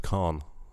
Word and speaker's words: Caen: I went over to, I think it's pronounced Caen, C-A-E-N, in northern France.